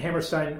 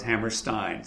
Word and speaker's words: Hammerstein: No, but Bill Hammerstein said to me, like Steinway pianos, Hammerstein. So that's the thing to remember, but it doesn't really matter.